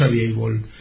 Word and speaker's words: available: no te available se pronuncia, ¿verdad? Sí, pone available, pero es available. Sí,